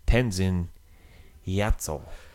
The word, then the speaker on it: Tenzin Gyatso: And his name is actually Tenzin Gyatso.